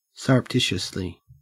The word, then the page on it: surreptitiously: Australian English